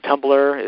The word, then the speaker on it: Tumblr: And Tumblr is spelled T-U-M-B-L-R. So there's no E on that, on there.